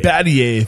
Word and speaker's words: Battier: Say his name for me. I don't want to destroy it. – Battier. – Thank you.